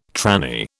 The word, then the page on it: tranny: British English